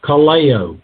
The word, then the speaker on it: kaleo: The word called comes from the Greek word kaleo, K-A-L-E, long O.